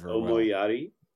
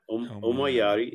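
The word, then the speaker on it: Omoiyari: It's pronounced Omoyari. Well. Yeah. Omoyari.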